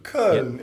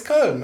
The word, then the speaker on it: Köln: Very good. Köln. Yep. It's almost perfect. Come on. – Tell him that, you know, when he makes a mistake, tell him. – Right. – No, it's Köln. It's great.